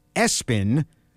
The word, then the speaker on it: ESPN: Another name, let's see, this name was spelled ESPN, but they pronounced it Espen.